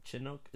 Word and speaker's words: Chinook: That I think the name of the helicopter is a Boeing Chinook. Chinook? Is that how you pronounce it? – I think so, yeah.